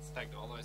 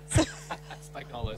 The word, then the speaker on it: Spagnolo: So there's the authentic one where the G would be silent, or there's the Anglo one, which would just be Spagnolo, which is quite Spagnolo, okay.